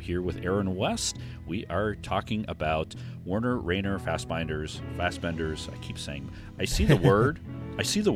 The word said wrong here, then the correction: Werner: Plus, it's Werner. – That's right. – Rainer Werner Fassbender.